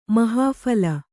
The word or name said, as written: ಮಹಾಫಲ